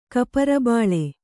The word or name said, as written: ಕಪರಬಾಳೆ